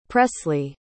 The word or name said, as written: Pressly